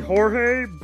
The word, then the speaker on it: Jorge: George wasn't. – It's actually Jorge, but General Hero can't pronounce it correctly because he's not Latino enough, so everyone just calls me George.